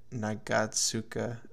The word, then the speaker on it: Nagatsuka: When um when Ishida was like, "Oh, uh, that's not that's not a boy," to uh, Nagutsu na, Nagatsuka. – His friend. I'm yeah. butchering these Na- names. Nagatsuka.